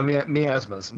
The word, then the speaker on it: miasmas: and monstrous miasma. Did I say that right? I guess so. – Yeah, miasmas.